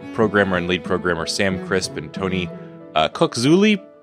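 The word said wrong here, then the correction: Coculuzzi: Please tell me I pronounced Cookzuli right. No, it's definitely Cookluzzi. – Cookluzzi, yeah.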